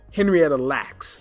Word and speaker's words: Henrietta Lacks: her name is Henrietta Locke. Okay? Remember that. Google her. Did I say it correctly? Lacks? Henrietta Lacks.